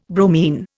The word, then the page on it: bromine: Indian